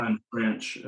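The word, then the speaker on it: Fama-French: Fama Finch.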